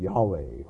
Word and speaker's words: Yahweh: Some Bible versions, it'll be Jehovah or something like that. But again, the pronunciation is probably more like Yahweh, the eternal one, the one who always exists, the one who was and is and will be there.